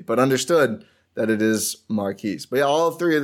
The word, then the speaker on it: Marques: So Marquise Bolden. – I thought it was Marcus. – No. – Is that you hearing from that from the broadcast or from outside sources? – From the pronunciation guide.